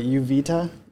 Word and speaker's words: Uvita: I think I'm saying it right. Uvita, U-V-I-T-A.